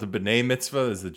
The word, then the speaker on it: B'nai Mitzvah: which is weird because it doesn't rhyme with like bat mitzvah, you know what I mean? It rhymes with b'nai mitzvah.